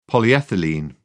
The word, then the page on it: polyethylene: British English